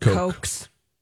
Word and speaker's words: Koch: How do you say this? The Koch family? The Koch family. – No, Kochs. – K-O-C-H. Koch.